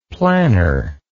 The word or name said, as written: planner